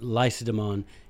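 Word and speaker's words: Lacedaemon: I am by nature Spartan, not laconic, but Lycidemon is how they pronounced that. That's what they called their own city, by the way, Lycidemon.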